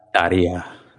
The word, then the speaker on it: Tarja: I was so sad when I call it Tarja, but I assume it is actually pronounced like Tarja or something. – Yeah, it's Tarja. – Yeah, she you roll the R and it's like a Y sound.